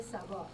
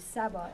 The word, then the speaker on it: Saba: My name is Manije Saba, M-A, if you want to spell it, M-A-N-I-J-E-H. The last name is Saba, S-A-B-A.